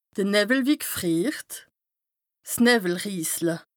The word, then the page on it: de Nëwwel wie g'frìert, 's Nëwwelrìesle: Bas-Rhin; Strasbourg